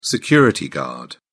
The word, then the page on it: security guard: British English